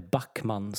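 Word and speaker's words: Bachmann: Ja, sen har vi då som jag var inne på inledningen där, Bachman i Watford som, eh, eller Backman ska man uttala det, så att vi får det rätt här.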